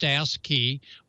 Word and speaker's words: ASCII: Yes, that is our CW Keir, and it's like a digital mode, which they call ASCII, pronounced ASCII.